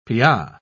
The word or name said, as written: pya